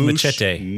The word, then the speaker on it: Muschietti: It's Mark Muschietti, if I pronounce Muschietti. – Muschietti? – No, it's Muschietti. – Muschietti. – Oh, Muschietti. Okay, Muschietti.